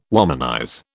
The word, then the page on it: womanize: British English